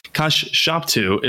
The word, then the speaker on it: Kashshaptu: For all of you listening, there's Kashshaptu is spelled with two iterations of SH. That's why I'm enunciating that with some emphasis on the wrong syllable. – You can't just say Kashshaptu, because it's Kashshaptu all squished together.